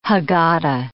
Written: Haggadah

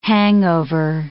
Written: hangover

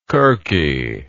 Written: kirke

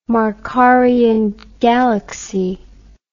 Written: markarian galaxy

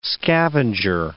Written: scavenger

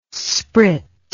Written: sprit

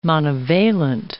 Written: monovalent

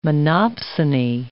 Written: monopsony

